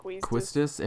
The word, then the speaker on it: Quistis: If you want to dress up as Quistis, is that how you say it? – Quistis.